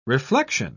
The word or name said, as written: reflection